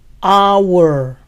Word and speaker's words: hour: La hora, hour, empieza con sonido de vocal, hour. No se pronuncia la H inicial, entonces the hour.